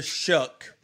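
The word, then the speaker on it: Shough: So look, here's the truth, is that I believe that Tyler Shuck had the, did I say it wrong again? – No, you got it. You nailed it.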